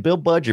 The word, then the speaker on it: Budge: And we can thank Bill Budge. Is it Bill Budge or Bill Budgie? – Bill Budge, I believe, is how you say it.